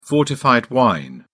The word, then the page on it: fortified wine: British English